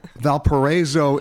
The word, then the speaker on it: Valparaiso: Valparaiso, Indiana.